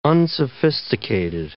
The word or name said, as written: unsophisticated